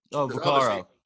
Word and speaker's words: Vaccaro: And I thought, I always forget how to say his name, Vaccaro.